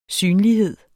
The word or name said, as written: synlighed